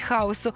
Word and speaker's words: хаос: Отже, безмежний світовий простір, що являв собою суміш усіх стихій, тобто, ну, незрозуміло що, але щось таке було, це хаос, наголос на О.